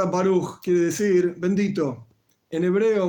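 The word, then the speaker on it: Baruch (ברוך): En hebreo sencillo, se en hebreo moderno se dice Baruch. El hebreo, como pronuncio yo, por ejemplo, es una pronunciación ashkenazí del estilo rusa, se dice boruch, no baruch, boruch. Hay otro tipo de pronunciación, húngara, por ejemplo, burij.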